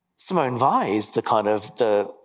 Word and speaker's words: Weil: She was her surname is spelt W-E-I-L, but yep. pronounced Weil.